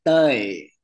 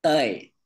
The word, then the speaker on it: Toey: I would say you can pronounce it Toey because my real name is Toey. – Oh, I won't be able to say that. – Yeah, it's Toey. It's like one syllable. – Ah, say it one more time. – Toey.